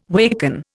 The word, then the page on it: waken: Indian